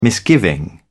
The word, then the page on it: misgiving: British English